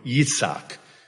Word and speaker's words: Yitzhak: Throughout the Old Testament, we see this with names like Isaac, pronounced in Hebrew, Yitzhak.